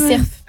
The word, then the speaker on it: cerf: Mais du coup, arrête-moi si j'ai dit cerf. – Ah oui, cerf. Ouais. Ah, dans le sud-ouest, on prononce toutes les lettres. – Et dis le F.